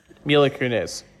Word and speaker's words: Mila Kunis: Mila Kunis.